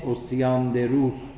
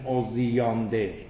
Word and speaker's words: اوسیاندروس: بعد شاگرد این آدم آمد یک مقدمه ای بر این نوشت به نام اوسیاندروس. اوسیان، اینجوری باید تلفظ کنید. در بعضی کتاب ها میگن اوزیاندر.